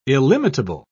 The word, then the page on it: illimitable: British English